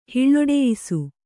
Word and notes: ಹಿಳ್ಳೊಡೆಯಿಸು: Kannada

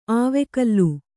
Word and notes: ಆವೆಕಲ್ಲು: āvekallu

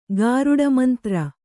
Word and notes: ಗಾರುಡಮಂತ್ರ: gāruḍa mantra

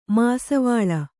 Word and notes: ಮಸ್ಸೀವಾಲ: Kannada

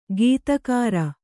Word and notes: ಗತ್ತುಗಾರ: gattugāra

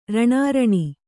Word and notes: ರಣಾರಣಿ: raṇāraṇi

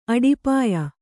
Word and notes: ಅಡುಪಾಯ: aḍupāya

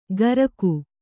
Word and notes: ಕರಗು: Kannada

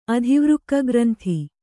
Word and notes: ಅಧಿವೃಕ್ಕಗ್ರಂಥಿ: adhivřkka granthi

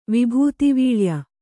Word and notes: ವಿಭೂತಿವೀಳ್ಯ: kannada

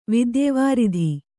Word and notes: ವಿದ್ಯಾವಾರಿಧಿ: vidyāvāridhi

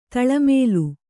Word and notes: ತಳಮೇಲು: taḷa mēlu

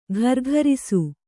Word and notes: ಘರ್ಘರಿಸು: ghargharisu

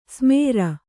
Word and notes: ಸಮರು: samaru

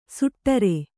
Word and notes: ಸುಟ್ಟರೆ: suṭṭate

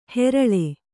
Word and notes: ಹೆರಳೆ: kannada

